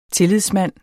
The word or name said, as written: tillidsmand